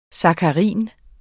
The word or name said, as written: sakkarin